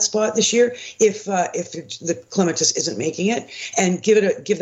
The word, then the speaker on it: clematis: No, he was right calling it clematis. It's properly pronounced clematis from the Greek word klema, to climb, because clematis is a climber.